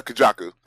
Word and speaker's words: Kujaku: Did I say that right? – Kujaku, but close enough. better than I've heard most people say it.